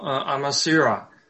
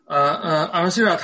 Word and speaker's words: Amasira: 它应该是叫做 Amasira， Amasira，